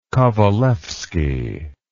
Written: kovalevsky

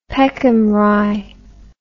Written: Peckham Rye

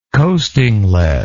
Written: coasting lead

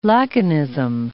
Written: laconism

